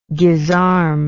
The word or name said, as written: guisarme